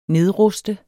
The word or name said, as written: nedruste